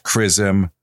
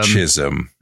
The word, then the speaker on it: chrism: This is actually pronounced Chrism, not Chisholm.